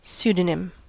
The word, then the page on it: pseudonym: American English